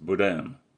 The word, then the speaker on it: Boudin: Den mest populära är Boudin Blanc och stavas Boudin, men uttalas Boudin.